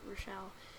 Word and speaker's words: Rochelle: I wonder if people think that my name is Rachel, because it's actually Rochelle. Because a lot of people expect Rochelle to be spelt with an O, like Rochelle, but it's not my version, at least.